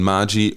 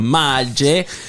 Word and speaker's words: Maggi: Gibt es einen Zusammenhang zwischen Maggi und Vor-Maggi? – Ich glaube nicht. Es ist nur die Doppel-G, wird so ausgesprochen. Es ist ein J im Italienischen.